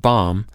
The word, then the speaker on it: bomb: We've already played with disappearing consonant sounds, no B sound at the end of bomb, and displaced vowel sounds, no O sound in history.